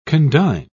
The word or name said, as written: condign